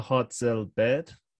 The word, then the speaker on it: Hartzell-Baird: Her name is Una Hartzell-Baird.